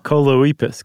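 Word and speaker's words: Choloepus: Coleoepus. Coleoepus. – Coleoepus? – It rhymes with Bocephus.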